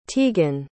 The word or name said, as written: tiegan